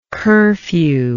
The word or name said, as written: curfew